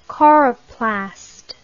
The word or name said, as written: coroplastic